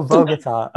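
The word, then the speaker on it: Bogotá: Bogota. – Oh, Bogota. Okay, whatever. – My stepmom is from there, so I know how to pronounce it.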